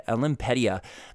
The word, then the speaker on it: Olympedia: Actually, quick, I'll read the Olymp- Olympic Olympia Media how do you say that? Olymp- Olympedia.